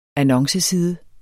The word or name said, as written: annonceside